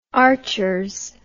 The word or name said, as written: archers